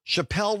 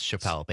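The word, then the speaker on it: Chappell: I would have also guessed Chappelle based on how it's spelled.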